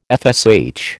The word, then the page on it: FSH: American English